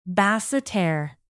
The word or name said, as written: basseterre